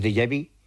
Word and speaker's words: déjà vu: y son cosas muy diferentes, que fenómenos como esos recuerdos de vidas anteriores, lo que llamamos déjà vu, que eso, mira que bien lo he pronunciado.